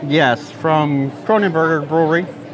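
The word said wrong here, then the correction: Kronenbourg: Kronenborg, Kronenborg, Kronen. Yes, it's Kronenborg.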